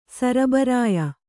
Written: ಸರಬರಾಯಿ

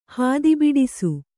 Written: ಹಾದಿಬಿಡಿಸು

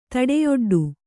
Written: ತಡೆಯೊಡ್ಡು

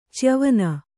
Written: ಚ್ಯವನ

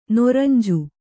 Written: ನೊರಂಜು

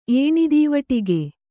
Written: ಏಣಿದೀವಟಿಗೆ

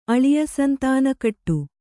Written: ಅಳಿಯಸಂತಾನಕಟ್ಟು

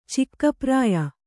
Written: ಚಿಕ್ಕಪ್ರಾಯ

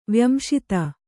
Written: ವ್ಯಂಶಿತ

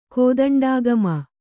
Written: ಕೋದಂಡಾಗಮ

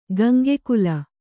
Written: ಗಂಗೆಕುಲ

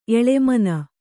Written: ಎಲೆಮನೆ

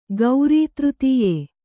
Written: ಗೌರೀತೃತೀಯೆ

